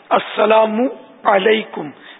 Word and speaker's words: السلام علیکم: السلام علیکم کہنا نہیں آتا، یہ تلفظ بھی درست کرنا ہے. السلام علیکم میم پر پیش بھی پڑھنا ہے.